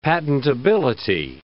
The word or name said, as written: patentability